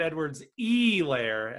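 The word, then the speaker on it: Edwards-Helaire: I placed a bet on Clyde Edwards Helaire to be Super Bowl MVP. You said uh, his name wrong. I, am sorry. Clyde Edwards E. Lair, as Steve Levy would say um, it was something similar, like ten bucks to win three grand or something, uh, the round before the Super Bowl.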